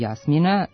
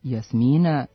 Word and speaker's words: Jasmina: Prema rečniku srpsko-hrvatskog književnog i narodnog jezika Srpske akademije nauka i umetnosti, ispravna su oba akcenta, i Jasmina sa kratkouzlaznim na prvom slogu i Jasmina sa dugouzlaznim akcentom na drugom slogu.